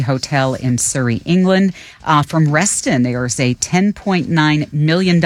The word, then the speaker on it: Reston: Oh, Reston.